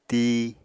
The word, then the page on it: tii: Apatani